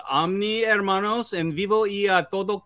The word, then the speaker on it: hermanos: He got the silent H. He didn't say hermanos.